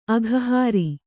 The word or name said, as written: ಅಘಹಾರಿ